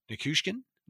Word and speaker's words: Nichushkin: Nichushkin? Did I say it right? – Yeah, that sounds right.